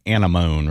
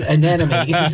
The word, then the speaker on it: anemone: Anemone. – Oh, sure. That's just anemone, right? A-N-E. – Yes. – M-O-N-E. Mm-hmm.